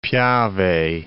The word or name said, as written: Piave